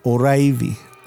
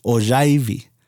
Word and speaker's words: Oraibi: because even the way that I say Oraivi, I have that rolling R that I can't say as well as Carl can in pronouncing that name of the village. But then, you know, I've heard the name Oraivi also be pronounced Ojaivi.